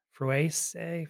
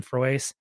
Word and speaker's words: Froese: It was by a couple authors named Paul Frues. I think that's how you say his name. F-R-O-E-S-E. Frues. I'm calling him Frues, and Christopher Bader.